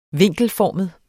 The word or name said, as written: vinkelformet